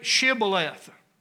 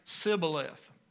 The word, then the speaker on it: Shibboleth: And when a man came by to wanting to cross, they would say, "Say this, say Shibboleth." And they couldn't say that. They'd say Sibboleth. They were tongue-tied in a way. They couldn't pronounce that word.